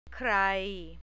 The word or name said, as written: krai